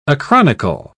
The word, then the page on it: acronycal: British English